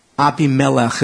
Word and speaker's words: Abimelech: Verse 22, it came about at that time that Abimelech, there's a million ways to pronounce that. Abimelech, some people say. Abimelech is how it would be in Hebrew.